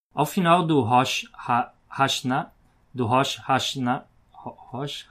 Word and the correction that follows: Rosh Hashanah: Tá errado, eu falei errado. É Hashanah.